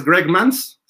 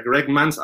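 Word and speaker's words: Greg Mancz: Pues es Greg Mans, se pronuncia así, él lo dijo en la conferencia, Greg Mans, aunque se escribe con C-Z,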